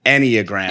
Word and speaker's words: Enneagram: Oh, wait, have we been saying it wrong the whole time? How do you say it? – Enneagram. – Enneagram. Okay.